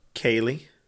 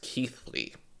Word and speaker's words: Keighley: So this town is spelled K-E-I-G-H-L-E-Y. How do you think it's pronounced? – Kaylee? – It's pronounced Keithley.